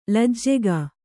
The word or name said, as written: ಲಜ್ಜೆಗಾ